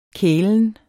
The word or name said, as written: kælen